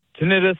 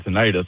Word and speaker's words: tinnitus: So I have tinnitus, I guess is how it's pronounced. I always said tinnitus.